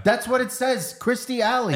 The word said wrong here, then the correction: Kirstie: It's Kirsty. Kerr.